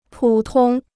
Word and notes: 普通: pǔ tōng

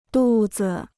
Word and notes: 肚子: dù zi

